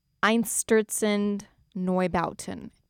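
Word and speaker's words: Einstürzende Neubauten: But the song that just finished, I gotta take a deep breath before I say this name. Just because it's famously difficult to pronounce, and it would make me look a little bad if I did a terrible job. But to my understanding, they are pronounced Einstürzen Neubauten. And the song I played was Newton's Gravity, I think is the English translation.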